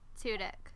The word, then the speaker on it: Tudyk: It's Alan Tudyk.